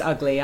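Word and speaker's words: Ugley: It's a silent E. – Yeah. – Pronounced Ugly.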